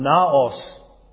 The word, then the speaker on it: naos: There are two words that could have been chosen for temple from the Greek: hieron and naos. The word that is chosen here is the second of those. In English you would spell it N-A-O-S. Naos.